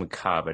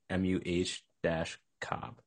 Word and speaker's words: macabre: I think it's pronounced macabre. I just Googled it. MUH-cob.